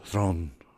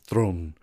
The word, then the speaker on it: Theron: And I guess you say her name, Charlize Theron. That's actually her name, Theron. Her last name is Theron.